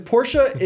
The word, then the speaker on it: Porsche: Because Porsche is how you say it in German, but then you say Mercedes like an American. See the problem? So you say Porsche like a German, but Mercedes.